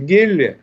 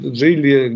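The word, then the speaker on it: Geely: Значит, э, китайский автомобиль, э, производитель Гелли, э, значит, Джилли, Гелли по-разному их называют, как бы читается, лишила импорт, импортированные в Россию машины заводской гарантии.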